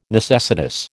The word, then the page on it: necessitous: American English